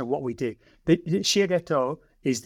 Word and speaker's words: Shiageto: And you are the founder and CEO of Shia Ghetto Consulting.